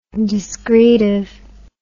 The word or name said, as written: discretively